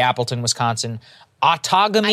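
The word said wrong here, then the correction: Outagamie: I think it's Outagamy.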